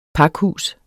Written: pakhus

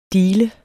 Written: deale

